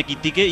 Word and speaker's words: Ekitike: Bueno, el otro día de hecho subieron un vídeo los jugadores del Liverpool diciendo la pronunciación de cada futbolista y yo me fijé en Kitiqué y es así, es tal y como se escribe, Kitiqué.